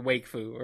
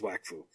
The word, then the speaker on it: Wakfu: We are talking about Wakfu. – Or Wakefu, or whatever you want to call it. – I think the common pronunciation is Wakfu.